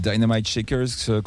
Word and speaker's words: Dynamite Shakers: Oui, les Dynamite Shakers qu'on a vu à l'aéronef il y a quelques jours également, ou Dynamite Shakers, comme ils le disent, ils gardent la prononciation française.